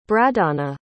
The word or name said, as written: bradana